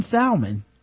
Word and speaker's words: salmon: One who, one who pronounces the L in salmon.